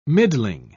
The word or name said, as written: middling